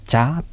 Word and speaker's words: จ๊ะ: はい、疑問文の場合はチャーと語尾を上げ、肯定文の場合はチャーと語尾を下げることになります。